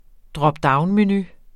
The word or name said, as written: dropdownmenu